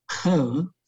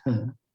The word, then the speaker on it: He: which is the fugitive He. It's Chinese pronunciation, and I don't even know how to write it, but I just write it as H E, but it's actually pronounced her.